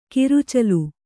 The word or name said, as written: ಕುರುಚಲು